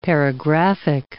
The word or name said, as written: paragraphic